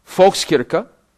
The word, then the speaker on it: Volkskirche: Volkskirche. The Volkskirche. Say, Volkskirche. Volks means people. That's a German word. It's with a V, but it's pronounced with an F. Volkskirche, like Volkswagen.